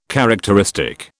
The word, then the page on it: characteristic: British English